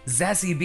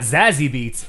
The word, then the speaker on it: Zassy: The subject is Zassy Beats. First name is pronounced Zassy. Like sassy. Ooh. So it's not Zazzy Beats, it's Zassy Beats.